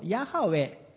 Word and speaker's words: ヤハウェ: 英語では YHWH、私たちのカタカナの母音が一緒の発音に直すとヤハウェと、まあ、今呼ぶことが許されていると思うんですが、まあ、そのヤハウェニシというふうに聖書にオリジナルテキストには書かれているんです。